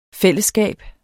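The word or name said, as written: FÆLLESSKAB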